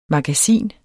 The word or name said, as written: MAGASINETS